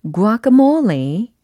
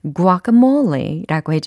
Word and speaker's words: guacamole: 과카몰리가 아닌 guacamole 이렇게 발음이 됩니다.